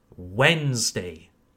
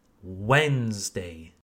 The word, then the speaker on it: Wednesday: The D and following E are silent. We don't pronounce them. Very simply, Wednesday. Not Wednesday, Wednesday. Once again, can you hear how I emphasize the first syllable? This is very common in the English language. Okay, so not Wednesday, Wednesday, the first syllable, Wednesday.